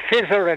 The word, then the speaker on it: Pfizer: P-F-Y-Z-E-R. They're calling it Fizzer and everything like that.